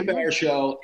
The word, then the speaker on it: Baruchel: I'm thinking, and I always pronounce his name wrong, and it's so embarrassing because I like him so much. Jay Brian, how many? – Jay Baruchel. – Jay Baruchel is publicly humiliated at school.